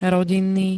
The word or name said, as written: rodinný